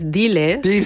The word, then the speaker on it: Diles: Dimash heißt das Stück. Habe ich es richtig ausgesprochen? – Nee, hast du falsch. Nein, falsch. Das heißt Diles.